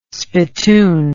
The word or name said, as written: spittoon